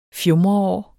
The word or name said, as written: fjumreår